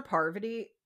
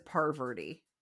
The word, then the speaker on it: Parvati: Okay, I will say, how hard is it for people to learn how to pronounce her name? Like, Jeff, I feel like he calls her Parvati at one point, but at the end, I think he says Parverty. Like, so many people do.